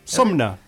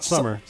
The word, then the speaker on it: Sumner: Sumner. Not Summer.